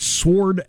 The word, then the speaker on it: sword: How, how, do you pronounce S W O R D? – Sword. Sword.